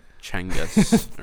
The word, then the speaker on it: Genghis: Is Genghis Khan start with a K? – No, G. It's Genghis Khan. – I've never learned It's supposed to be pronounced like Chengis or something like that.